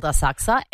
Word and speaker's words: Asakusa: There's a suburb in Tokyo called Asakusa, and it's spelt Asakusa. So phonetically, Asakusa. Yeah. I don't pronounce the U, and because I'm basically a local, I know that it's called Asakusa.